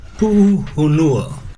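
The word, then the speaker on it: Puhunua (Puʻuhonua): But tell us the proper way to say that, Hawaiian brother. How we say the name of their movement. – Puhunua. It's Hawaiian for a place of refuge.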